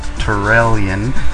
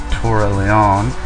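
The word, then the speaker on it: Turalyon: Anyways, for Jimmy, I looked up some Warcraft 2 sound files, and the namesake of our server is actually pronounced Torellion, not Torellion.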